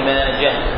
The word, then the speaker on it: ماجه: وماجه بالهاء بالتاء من الهواحي، واحدة ليست بالتاء إنما هي بالهاء، ونطقها بالتاء غلط، فهي وأخواتها بالهاء مندة وماجة. وأشرت إلى ذلك قولي على سبيل الملاطفة لأحدهم: يا صاحي نطق ماجة بالهاء بلا لجاجة، يا صاحي نطق ماجة بالهاء بلا لجاجة.